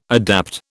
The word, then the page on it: adapt: British English